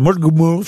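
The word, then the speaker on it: Mullaghmore: Une bombe télécommandée de 23 kilos a été placée près du moteur de l'embarcation dans une baie irlandaise, à Mulgmul. Je sais pas si je prononce bien. – Non, c'est très bien prononcé.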